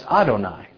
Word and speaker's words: Adonai: Rabbi Nachman ben Yitzhak says, "In this current age, the name of God is written as Yod and He with Vav and He, and we pronounce it, though, as Adonai.